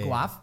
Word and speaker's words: Guelph: I grew up in a small city called Guelph. – Wait, that sounds made up. – Yeah. – Guelph? – It's like... Guelph, yeah. – Guelph? – Exactly.